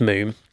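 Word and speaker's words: Múm: Now, this is from an Icelandic band called Moom, which is M-U-M, with one of those little lines over the U, but it's pronounced Moom.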